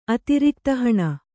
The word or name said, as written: ಅತಿರಿಕ್ತಹಣ